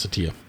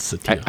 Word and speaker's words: Satya: And it's why I think that Satya, by the way, his name, it turns out, is pronounced Satya.